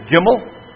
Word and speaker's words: Gimel: Next is Gimel, which is G, as in good.